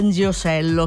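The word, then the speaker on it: Ndegeocello: Nziosello, che io spero si pronunci così, ho fatto una fatica, l'ho pronunciato bene,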